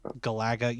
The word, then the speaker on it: Galaga: You put the wrong emphasis on the wrong syllable. It's got to be Galaga. – Right? – Galaxy. Galaga, Galaxy. Galaga.